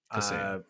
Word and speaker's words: Kaseya: The other big news story was, I'm not actually sure how to pronounce this company's name, Kaseya? – Kaseya.